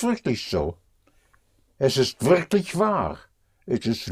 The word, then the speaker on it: wahr: War also means true and that is spelled W-A-H-R, but it has the same sound, same pronunciation as was.